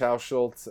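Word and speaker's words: Hauschildt: Yeah, so social media, Facebook is Mitch Hauschultz, and I'll spell that because people, well, you can probably get it in the notes, but I'll spell it anyway.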